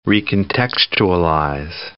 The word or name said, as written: recontextualize